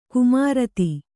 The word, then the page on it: ಕುಮಾರಿತಿ: kumāriti